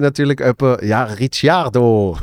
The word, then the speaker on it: Ricciardo: Aber er ist aus Australien. und, und, sie sagen Ricardo.